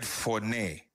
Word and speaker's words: phoné: You see, the original Greek word used for voice is the word phoné. P-H-O-N-A-Y is the pronunciation, phoné. But spelling is P-H-O-N-E, which is...